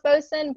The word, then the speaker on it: boatswain: It's pronounced bosun, but it's spelled boatswain.